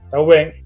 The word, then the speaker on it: Thauvin: Thauvin.